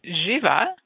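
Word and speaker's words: Živa: And um, Jiva, which is uh, spelt the same way, pronounced slightly different, goddess of fertility.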